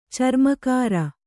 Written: ಚರ್ಮಕಾರ

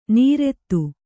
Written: ನೀರೆತ್ತು

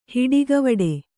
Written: ಹುಟ್ಟುಕಿವುಡ

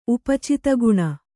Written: ಉಪಚಿತಗುಣ

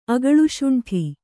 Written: ಅಗಳುಶುಂಠಿ

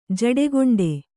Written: ಜಡೆಗೊಂಡೆ

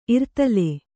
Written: ಇರ್ತಲೆ

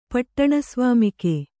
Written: ಪಟ್ಟಣಸ್ವಾಮಿಕೆ

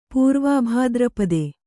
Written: ಪೂರ್ವಾಭಾದ್ರಪದೆ

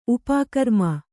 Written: ಉಪಗ್ರಾಮ

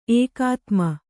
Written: ಏಕಾತ್ಮ

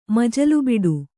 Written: ಮಜಲುಬಿಡು

